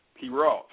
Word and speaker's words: Pierott: My last name is pronounced Pierrot. – Pierrot.